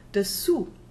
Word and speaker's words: dessous: Dessus, above or over, we have it here, par-dessus, D-E-S-S-U-S. Here the E is pronounced as a schwa. The same is true of the word dessous, underneath or below, spelled D-E-S-S-O-U-S.